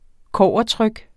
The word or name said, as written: kobbertryk